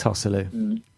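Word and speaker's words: Joselu: I think we should have some sort of system where if you pronounce the J in Hosselu, you have to put a pound in a ha, as in a jar. I think we have to stick to this. – It's Hosselu.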